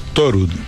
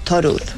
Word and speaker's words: Thorud: ¿Tu segundo apellido, cómo se pronuncia? – Thorud. – Thorud. – Como el dios Thor, porque es un apellido noruego.